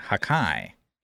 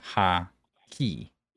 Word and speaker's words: hockey: Broken, you mispronounced the word hockey, saying hakai instead of ha-ki.